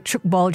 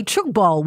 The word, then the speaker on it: tchoukball: Now, in case you're wondering, how do you play chukball? What is it? Well, firstly, it's spelled T-C-H-O-U-K-B-A-L-L, and it's